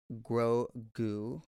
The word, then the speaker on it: Grogu: They have a plan to get follow Moff Gideon's cruiser because he has little baby Grogu.